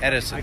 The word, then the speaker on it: Edison: Doug Edison. Okay. E-D-I-S-O-N.